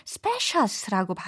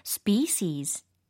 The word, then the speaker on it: species: 그래서 같은 종이라는 부분에 그 종에 해당하는 단어가 S-P-E-C-I-E-S 라는 e e 철자예요. 이거를 가끔 잘못 발음하시는 분들은 specious라고 발음을 하시더라고요. specious가 아니라 species, species 이렇게 발음을 해주셔야 됩니다.